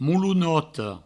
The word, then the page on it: molonota: moulounoto